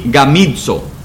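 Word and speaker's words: gamizo (γαμίζω): La palabra griega es, se pronuncia gamizo. Gamizo.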